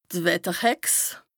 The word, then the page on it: d' Wetterhex: Bas-Rhin; Strasbourg